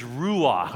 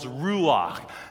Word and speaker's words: ruach: It's two syllables, ruach. Say ru, say ah. It's in the back of your throat. Do a ch. Go ch. Ruach.